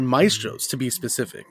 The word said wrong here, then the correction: Mastro's: I believe it's Mastro's.